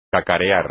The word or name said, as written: cacarear